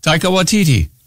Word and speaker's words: Waititi: Pronounce it again. Taika Waititi. – Okay. I thought it was like take a titty.